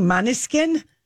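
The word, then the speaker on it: Måneskin: How do you say their name? Maneskin. The Italian.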